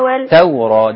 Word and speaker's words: التوراة: ويعلمه الكتاب والحكمة والتوراة والتوراة، دي لام اسمها لام شمسية ما بتتنطقش.